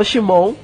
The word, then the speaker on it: Shimon: O japonês fala Shimon, se escreve Simon. Eu não sei se eu devo falar Simon ou Simon. O que eu devo falar? Ele fala Shimon.